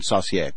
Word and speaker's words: Saucier: It's the French pronunciation, Saucier.